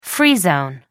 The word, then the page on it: free zone: American English